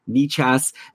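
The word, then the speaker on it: Nečas: And yeah, Nechas, who, by the way, I'm pretty sure we're pronouncing correctly. It's spelled N-E-C-A-S, but I think we looked this up before when we were talking about him last summer, and it is Martin Nechas.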